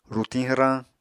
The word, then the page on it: rutiñ hwrañ: Apatani